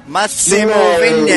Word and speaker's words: Vignelli: Atenção para a pronúncia. Máximo Vinelli, não Vignelli, porque tem aquele G. Mas não é Vinelli? Não, né? É Máximo Vignelli. É porque é italiano.